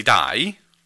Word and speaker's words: Di: So as a counterpoint to Lady Di, for us, Lady D, okay. – Because it's D-I, so you spell it in Spanish, D.